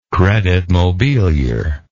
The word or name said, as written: credit mobilier